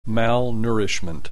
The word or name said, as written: malnourishment